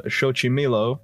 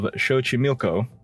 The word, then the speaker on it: Xochimilco: It is located in the canals of Xochimilco, or I know I butchered that one. Located in the canals of Xochimilco. There we go.